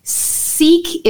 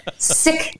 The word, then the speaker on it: Sikh: And so Sikh is not the right way to say it. – Perfect. – Sikh is the right way to say it. Okay. And you know, my fellow Sikhs, we say it wrong. Uh-huh. And we do this because we don't want to make other people uncomfortable in how they pronounce words. But it actually is Sikh and Sikhi or Sikhism. Mm-hmm. It's not Sikh or Sikhism or Sikhi.